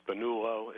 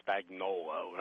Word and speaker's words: Spagnuolo: When he was our coordinator last time, so I'm interviewed, and he said, hey, it's the Italian pronunciation. It's Spagnuolo. It's not Spagnuolo, right?